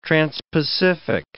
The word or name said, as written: transpacific